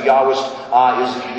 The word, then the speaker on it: Yahwist: That actually uses the letter J, because the original research that was done on this came from Germany, and they use J as a Y. Right? They pronounce it that way. So Yahwist is J.